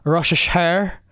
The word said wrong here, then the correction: Ferrero Rocher: Rocheshire chocolate. Another Ferrero Rocher. – Ferrero Rocher. Yeah, that.